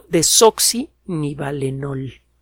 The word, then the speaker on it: desoxinivalenol: que es el deoxinidan de desoxinivalenol. – Ahora sí se lo dije bien.